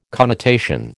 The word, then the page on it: connotation: American English